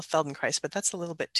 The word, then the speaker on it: Feldenkrais: Feldenkrais rhymes with paradise. So it's Feldenkrais.